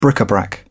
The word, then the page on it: bric-a-brac: British English